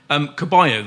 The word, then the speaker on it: Ceballos: Caballo, then, as is the proper pronunciation, spelt Ceballos. Caballo, is he an equivalent of a Ramsey?